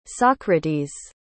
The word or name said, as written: socrates